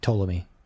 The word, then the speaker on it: Ptolemy: And by the way, the P in Ptolemy is silent. It's just Ptolemy.